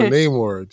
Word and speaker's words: Namor: And I'm not pronouncing it the MCU way because he's not the MCU character. And so Namor, just...